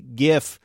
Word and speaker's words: GIF: By the way, I asked Narayen whether he says the word for animated images with a soft G or a hard G. Point for Marketplace's Kai Rizdal, the CEO of Adobe, uses a hard G, GIF.